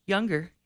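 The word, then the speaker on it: Junger: I'm not sure how to pronounce his last name, but I believe it's Sebastian Younger. – You pronounce it perfectly. Younger, yeah.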